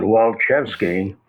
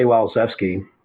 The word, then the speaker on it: Walczewski: You know, my last name sounds like a sneeze. Jeff Krause used to pronounce it Walczewski. I say Walczewski.